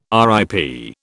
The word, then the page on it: RIP: British English